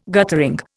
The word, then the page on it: guttering: Indian